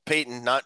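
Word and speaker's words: Paton: So the other big news in Vikings land, George Payton, it's P-A-T-O-N pronounced Payton, no relation to Walter or Eddie for that matter.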